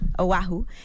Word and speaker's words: Oahu: He was staying in Oahu. Is that how you say it? I've never been to Hawaii, period. – Oahu? – Oahu.